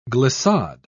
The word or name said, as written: glissade